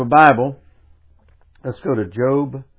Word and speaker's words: Job: It's spelled like the word job, but it's pronounced Job, a long O.